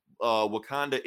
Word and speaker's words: Wakanda: Wakanda is now a democracy, you know, it's no longer a monarchy. Did I say it right? I said it right this time.